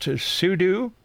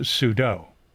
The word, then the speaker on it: sudo: The folks who say it should be sudo compare it to judo.